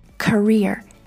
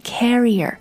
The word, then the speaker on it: career: career C-A-R-E-E-R career 重音是在第二个音节上 career 千万不要读成 carrier Carrier is something else. A carrier, C-A-R-R-I-E-R.